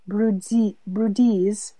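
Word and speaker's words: Bryde's: But NHMAD now has 5 Emirati assistant curators studying everything from the spiders of the Arabian Peninsula to a 50-foot-long bride's pronounced Brudese whale skeleton that turned up in a mangrove forest 15 minutes from the museum.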